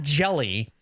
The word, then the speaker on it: Hjelle: The Giants have more players on their roster with silent H's at the beginning of their name than any other team in baseball. Sean Hjelle